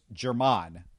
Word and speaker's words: Germone: It's spelled G-E-R-M-O-N-E, but according to the pronunciation guide I found, it was Jermon. – You are correct. That's how you pronounce it.